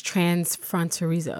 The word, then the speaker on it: transfronterizo: Even more specifically, I know part of your identity, as you describe it, is transfronterizo. Am I pronouncing that improperly? – No, I think it sounds great.